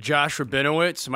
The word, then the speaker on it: Josh Rabinowitz: Josh Rabinowitz.